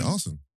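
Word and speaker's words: Arsène: How you pronounce it. – Yeah, Arsene.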